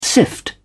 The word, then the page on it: sift: British English